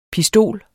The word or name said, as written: pistol